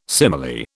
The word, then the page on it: simile: British English